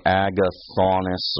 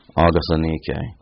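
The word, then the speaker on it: Agathonike: Agathanike is how you pronounce that word, that name. And it looks like you'd say Agathonis or something, and that's probably how in English you would anglicize that. But I'm giving you the Greek pronunciation of that, and there it is phonetically spelled for you. Agathanike, or Agathanike.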